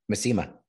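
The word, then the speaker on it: Masema: Has Maesma, how do you say it? – Maesma. – Maesma, thank you.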